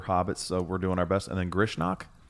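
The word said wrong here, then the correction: Grishnákh: Grishnak. – Is how I would say that. – Yeah. Grishnak.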